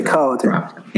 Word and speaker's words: Colliton: Uh, Colliton, I believe. – Okay, Colliton. – Yeah.